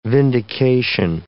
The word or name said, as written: vindication